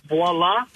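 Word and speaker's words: voilà: The word voila, I oh. used to, like, 20 years ago, writing emails, type W-A-L-A. – Yeah. – Voila. – Voila. – I mean, that's how it's pronounced.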